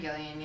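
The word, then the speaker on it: Gillan: And Nebula, like, first off, Karen, how do you say, Gillian? – Karen Gillian, yeah.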